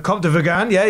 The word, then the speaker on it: Comte de Vergennes: And one of the first people he meets there is one of the most powerful men in France, the Comte de Vergennes. – Yeah, the Comte de Vergan. Nice chap, yeah. – Once again with the French pronunciation making me look bad.